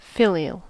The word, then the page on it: filial: American English